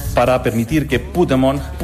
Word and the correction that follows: Pokémon: El poder que hay en Pokémon.